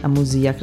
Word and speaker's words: Amusia: E invece di Fiction, che sono andati bene, la locandina di Ride Above e Amusia, con l'accento sulla I, Amusia, credo si pronunci così,